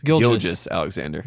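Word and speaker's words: Gilgeous-Alexander: Gilgeous Alexander.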